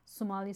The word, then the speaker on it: Somali: Could you give me your honest opinion about how this Korean movie depicted Somalian or Somali? – Somali is how you say it.